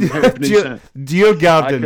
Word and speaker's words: Djurgården: Djurgården.